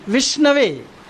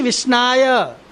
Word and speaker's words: विष्णवे: इदम विष्णवे, वो उसका उच्चारण क्या है, विष्णवे, हाँ, विष्णु को दिया, विष्णवे, हाँ, पंडितो वदति विष्णवे, मूर्खो वदति विष्णाय।